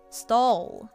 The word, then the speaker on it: stall: Stall。 – Hey, not bad。 – Stall。 – Stall。 – この時口の形はおの形よりあの形をした方が言いやすいかもしれません。